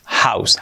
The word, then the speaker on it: house: Lo primero, house, escrito house, la pronunciación house, house, house. House, house, realmente tiene una O, una U y una E, pero es una A y una U. House. Así suena.